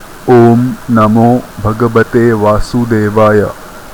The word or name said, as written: Om Namo Bhavate Vasudevaya